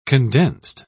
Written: condensed

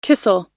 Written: kissel

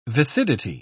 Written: viscidity

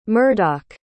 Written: murdock

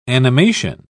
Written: animation

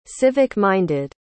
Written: civic-minded

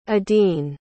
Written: edeen

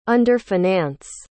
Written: underfinance

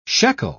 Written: shekel

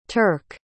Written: turk

